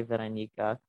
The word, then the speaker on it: Vironika: And actually, like, now when I meet people and they ask what my name is, I say Veronica, but it's hard to spell. But yeah, I've started saying my name like how I say my name.